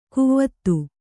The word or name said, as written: ಕುವತ್ತು